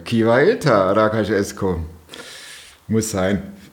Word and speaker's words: rakas: Okay, wie spricht man es richtig aus? – Ja, das heißt, schönen Abend, lieber Esko. – Rakas Esko. – Hyvä ilta, Rakas Esko heißt.